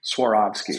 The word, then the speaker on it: Swarovski: Swarovski.